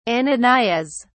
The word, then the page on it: ananias: British English